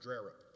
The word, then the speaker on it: Drerup: And to accomplish this goal, Captain Drerup, and her name is pronounced Drerup, by the way. She's from the panhandle like I am, and they pronounce things their own way up there, even though the name doesn't look like Drerup.